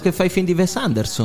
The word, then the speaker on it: Wes Anderson: Se tu mi dici che si dice Wes Anderson, ti chiedo scusa, ma si dice Wes Anderson.